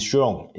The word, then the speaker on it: Chiron: Chiron，OK，呃，最后一辆的布加迪 Chiron 已经呃正式的离开产线，那就是这个布加迪呢，近日宣布限量生产的第五百辆 Chiron 已经顺利的离开其产线，并且交付给一位加拿大籍车主。